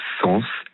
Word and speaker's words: Saens: Je m'appelle Roman, mais ça s'écrit roman, comme un livre, R-O-M-A-N, tiré du bas, sens, S-A-E-N-S, comme le compositeur Camille Saint-Saëns, roman, tiré du bas, sens, S-A-E-N-S, et sur Instagram et TikTok.